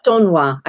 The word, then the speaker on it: d'Aulnoy: So you understand that you've got a current project working on the fairy tales of Madame, now let me get this right, Madame de Aulnoy, is that how you say her name? – D'Aulnoy, I think, is a pronunciation. D'Aulnoy, okay.